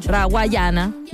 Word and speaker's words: Rawayana: Nosotros los tocamos mucho, así que es parte de este lineup, donde también lo comparte David Guetta, Rayawana, yo siempre lo digo mal, Rawayana.